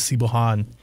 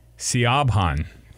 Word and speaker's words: Siobhan: Like, yeah, for the longest time, I would see that, like, read it in books, and I thought it was Sibohan. – Oh. – Well, it wouldn't be Sibohan anyway. It would be Siobhan. S-I-O-B-H-A-N.